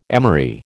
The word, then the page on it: emery: American English